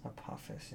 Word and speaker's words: Apophis: Apophis, yeah.